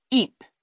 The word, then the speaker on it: Eype: We could see east past EYPE, pronounced Eep, to Portland Bill curving out grandly, and west, past Lyme Regis, to the far blue coasts.